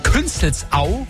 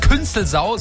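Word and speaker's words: Künzelsau: Da haben wir zum Beispiel Künstelsau. Heißt nicht Künstelsau, sondern Künstelsau.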